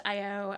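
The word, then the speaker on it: Eyeo: I O E. – Uh, okay. So it's I. – It's it's pronounced I O.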